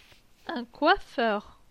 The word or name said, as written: Un coiffeur